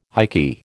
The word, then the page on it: ikky: American English